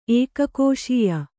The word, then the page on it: ಏಕಕೋಶೀಯ: ēkakōśīya